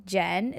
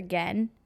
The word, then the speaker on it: Gen: Jen. Is that how you say it? Or Gen? – Either one.